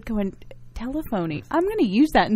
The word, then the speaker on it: telephony: And Marius has a diverse background as well, but on the technology side, I'm very anxious to find out what the word telephony means. – Well, good morning, first of all. Good morning. – It's actually telephony. – See, I'm even saying it wrong.